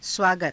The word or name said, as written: svaagata